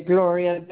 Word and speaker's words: Gloria: Gloria. – Spell that. – Okay, Gloria. The way G-L-O-R-I-A. Yes. – Okay, and your last name? – Riz Silva. Riz, R-U-I-Z.